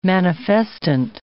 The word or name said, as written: manifestant